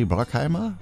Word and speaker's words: Bruckheimer: Ich glaube, das wird ja amerikanisch. Da wird das irgendwie A ausgesprochen, ne? Jerry Bruckheimer, würde ich mal so sagen.